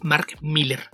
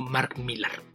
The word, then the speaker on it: Millar: Solemos decir Mark Millar cuando en realidad es una variación de Miller. Nada más se escribe diferente, pero se lee igual. Entonces, este autor que le vendió sus propiedades de cómics a Netflix, creador de Wanted, Kick-Ass, Jupiter's Legacy y muchas otras cosas, en realidad es Mark Miller, no Mark Millar.